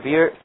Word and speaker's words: bier: Okay, what is haram is to accompany the buyer. How do you pronounce it? Buyer or beer? – Beer. – Same thing as the drink. It is not allowed for them to accompany the bier to the graveyard, and it is not allowed for them to enter the graveyard.